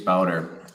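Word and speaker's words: Bauder: Is it Bowder? Is that how you pronounce his last name? – Yeah, exactly. James Bowder.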